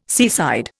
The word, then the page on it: seaside: Indian